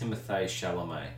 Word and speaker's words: Timothée Chalamet: Timothy, I apologise if we're mispronouncing I think it's pronounced Timothée Chalamet.